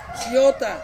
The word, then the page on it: siota: Ansus